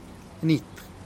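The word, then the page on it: nit: Teotitlán del Valle Zapotec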